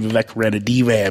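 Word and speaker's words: Vivek Ranadivé: Vivek Ranadive, I believe is the correct way to say that.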